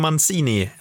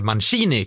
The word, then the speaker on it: Mancini: För igår blev det ju klart att Roberto Mancini, eller Mancini kanske han ska uttalas egentligen, tar över som Italiens förbundskapten i fotboll.